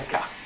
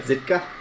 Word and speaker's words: Zidka: Zidka. Z-I-D-K-A. – Zidka? – Zidka. – Rhymes with Ditka.